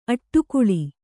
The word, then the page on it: ಅಡಿಗಲ್: Kannada